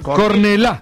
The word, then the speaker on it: Cornellà: Cornellà. – ¿Qué? – Con acento en la Cornellà.